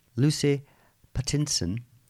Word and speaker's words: Lucy Pattinson: Lucy Pattinson, have I said that right? – Yes.